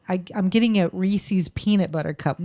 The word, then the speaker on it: Reese's: I hate it when people mispronounce Reese's and Reese's peanut butter cup. – What do they say? – Reese's. – Oh, Reese's. Yeah, I have heard that. I say Reese's, but yeah. Oh, you can't be mad at anybody pronouncing anything wrong. – I don't know how they do it, though. It's E-E. – Yeah. Yeah. – Reese's. Not Reese's.